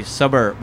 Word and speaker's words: Sabar: So I guess it depends on your dialect, right? So I speak Urdu fluently. So for me, how I would pronounce it and people that live in Pakistan would pronounce it would be Sabar Barbecue, which means patience, but it doesn't quite roll off the same for everybody.